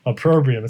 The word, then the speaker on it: opprobrium: Opprobrium. It's such a weird word. If you saw it, you probably mispronounced it. This is a bizarre looking word. It's spelled O-P-P-R-O-B-R-I-U-M. So it's opprobrium. Opprobrium. O-P-P-R-O-B-R-I-U-M.